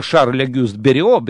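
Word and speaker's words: Шарль Огюст Берио: А, кстати, Шарль Агюст Берио, надо правильно говорить, это любой скрипач, который учится в любой музыкальной школе России и мира, это имя знает.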